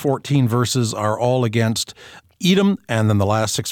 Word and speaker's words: Edom: Now, with Edom, we supply the vowel sounds of E and O. With Adam, we supply the vowel sound of A. So you got Edom and Adam, Edom and all people, Edom and all humanity.